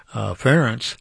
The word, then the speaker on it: Ferenc: Well, I thought, yesterday Nathan and I learned how to pronounce the Hungarian name, Ferenc, as Ferenc.